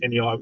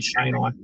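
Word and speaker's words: cheynei: And in both of those papers, they basically give the recommendation is that there's no genetic differences between, say, MacDowelli and Shani, which is usually pronounced Chenai, which is incorrect. It's actually Shani for the jungle carpets.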